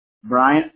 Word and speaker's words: Bryant: Bryant, B-R-Y-A-N-T. – Got it. Chad, you spell that C-H-A-D, right? – That's right.